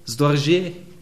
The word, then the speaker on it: Storge: Das Wort, was hier gebraucht ist, kommt von dem Wort Storge, oder Poppy, wie spricht man es richtig aus? Ja? Storge. Klingt viel französischer eigentlich.